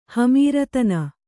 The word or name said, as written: ಹಮೀರತನ